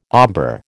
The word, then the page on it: ombre: American English